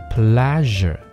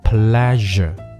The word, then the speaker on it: pleasure: 和第二个 pleasure 这个发音呢，大家可以在卡片里面可以看到这个音标是 z，不是 sh，所以这个词念成 pleasure，不是念成 pleasure。